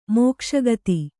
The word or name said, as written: ಮೋಕ್ಷಗತಿ